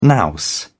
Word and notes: nous: British English

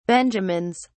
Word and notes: benjamins: British English

